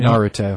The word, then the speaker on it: Naruto: Moving on, PS2 gets, I'm going to pronounce it wrong, Naruto? Somebody help me Naruto.